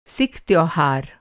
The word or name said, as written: सिख